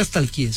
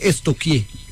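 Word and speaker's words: stalkeé: Porque no se dice nunca estalquié, se dice estoquié. – ¿Estoquié? – Sí. Pregúntale a la profe, verá. A mí una vez estamos estoquiando. – Sí, estoquiando. Yo, pero se escribe estalquear. Es estoquear.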